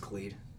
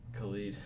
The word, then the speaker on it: Khalid: Number seven, I had Free Spirit by Khalid. A song on... – I don't know. – It's pronounced Khalid. – Ooh. – Khalid.